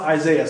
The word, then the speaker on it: Isaiah: And you know, when I read God's word, if you get into like Isaiah, I love Isaiah. See, I'm saying it right for you.